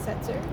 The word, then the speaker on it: Setzer: Is it K-R-I-S-T-A? – Yes. – And what's your last name? – Setzer. – Setzer. Can you spell that for me? – S-E-T-Z-E-R. – One more time. S-E-T...